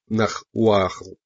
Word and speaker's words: нагваль: Нагваль произносится как Нахуахл, название, дававшееся или животному, в которое маг мог превращаться, или тому магу, который практиковал такие превращения.